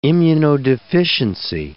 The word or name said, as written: immunodeficiency